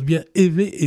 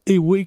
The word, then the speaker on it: éwé: Je voudrais revenir sur la prononciation de l'évé. E accent aigu, W et accent aigu. Ça se prononce bien évé et non pas éwé comme on l'entend fréquemment quand on parle de cette langue-là.